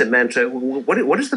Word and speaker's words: mantra: Okay, well, the mantra is... – Did I say it wrong? – It's mantra's fine. Mantra.